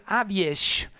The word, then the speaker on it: AWS: La banda se deia AWS, que se pronuncia Aviesh, aquesta formació de metal i post-hardcore d'Hongria que havien debutat en el 2006 amb aquest títol Bislat Nihar.